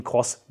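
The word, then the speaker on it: XROSS: Und genau, das ist eben die X, also Cross-Drohne heißt die, also X-Ross, werdet ihr im Titel auch sehen, wird aber als Cross ausgesprochen, also als Kreuz.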